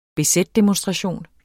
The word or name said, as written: bz-demonstration